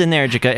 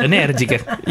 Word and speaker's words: Energica: Yeah, it's so the bike is Energica. It rhymes with America. Just change the C to a G. You got it. – Energica.